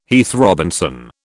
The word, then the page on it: Heath Robinson: British English